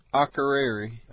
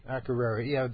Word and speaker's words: Akureyri: I hope I'm pronouncing that right. – Akureyri. – Akureyri, yeah, the capital of the north of Iceland.